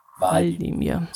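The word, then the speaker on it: Hvaldimir: Die norwegische Bevölkerung sollte ihm einen Namen geben und hat sich zu 37% und jetzt kommt das Norwegische für Valdimir entschieden. Ich nehme mal an, das H, das spricht man gar nicht. – Genau, uns dann auch. Der heißt nicht Wladimir, sondern Valdimir.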